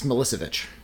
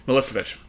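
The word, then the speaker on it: Milićević: I'm going to butcher this, Milicevic, who had, around that time, was doing... – I think it's Milicevic. – Milicevic.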